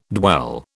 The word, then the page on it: dwell: British English